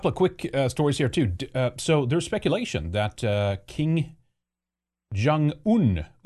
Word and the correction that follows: Kim Jong-un: is that how you pronounce his name? Kim Jong-un is either deceased or he's fallen very, very ill.